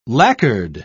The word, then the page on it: lacquered: British English